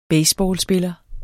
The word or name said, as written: baseballspiller